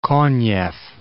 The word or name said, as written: Konev